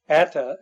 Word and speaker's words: Atta: Atta, actually, is what he prefers to have his name pronounced. But as the consensus panel has looked into it, there apparently were two different individuals going by the name of Muhammad Atta.